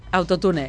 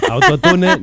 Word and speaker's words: autotune: Autotune, eso. – No, autotune no. Pronunciem bé, és autotune, que no costa res dir les coses bé.